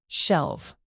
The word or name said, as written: shelve